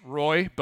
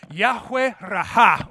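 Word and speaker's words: Yahweh Ra'ah: Phonetically, it's Roy, but that's a super American pronunciation of that word. We're like, no, these people are Hebrew. They have the Raha. So, Yahweh Raha.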